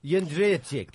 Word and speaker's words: Jędrzejczyk: Jędrzejczyk.